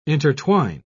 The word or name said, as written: intertwine